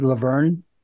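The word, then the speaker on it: La Verne: Laverne, California.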